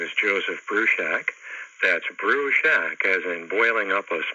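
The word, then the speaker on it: Bruchac: It's Brushak.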